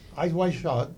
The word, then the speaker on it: Eyes Wide Shut: Kidman, ustedes saben que es la actriz que coqueteó con la infidelidad en Eyes Wide Shut, de 1999. Probablemente es así. Mira, lee cómo es para que lo diga bien. – Eyes Wide Shut. – Ah, lo dije bien.